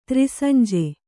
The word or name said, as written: ತ್ರಿಸಂಜೆ